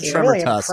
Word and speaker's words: Tremortusk: I think it's Tremortusk. Really yes. – Is it? Tremortusk!